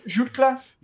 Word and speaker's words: Jutglà: He is, and not Ferran Torres, just Ferran, say his name, Jutkla.